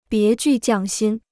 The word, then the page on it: 别具匠心: bié jù jiàng xīn